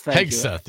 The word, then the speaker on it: Hegseth: Hegseth, yes.